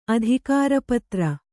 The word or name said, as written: ಅಧಿಕಾರಪತ್ರ